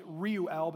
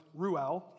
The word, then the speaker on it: Reuel: His name is pronounced actually Reuel, but that's really hard to say, so we're going to run with Reuel.